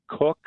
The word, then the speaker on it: Koch: One is by Dr. Kathy Cook, and Cook is spelled K-O-C-H, so not the way we normally think of it.